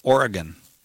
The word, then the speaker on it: Oregon: Kevin in Portland, Oregon. That's the Yeah. – correct pronunciation, you know. Oregon.